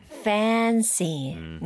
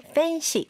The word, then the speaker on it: fancy: Fancy. 네, 여러분 발음을 fancy 이렇게 하지 말고 A를 늘어지게 fancy.